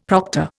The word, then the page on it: proctor: Indian